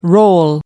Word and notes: role: American English

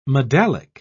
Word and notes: medallic: British English

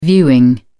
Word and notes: viewing: British English

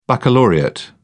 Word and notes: baccalaureate: British English

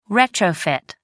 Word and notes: retrofit: American English

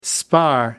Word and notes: spar: American English

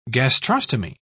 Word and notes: gastrostomy: British English

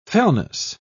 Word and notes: fellness: British English

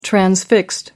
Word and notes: transfixed: British English

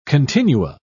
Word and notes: continua: British English

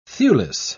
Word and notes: thewless: British English

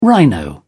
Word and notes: rhino: British English

